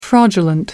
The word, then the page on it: fraudulent: American English